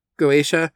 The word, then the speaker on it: Goetia: Is it Goetia or like Goetia? – Yeah.